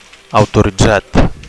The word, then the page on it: autoritzat: Catalan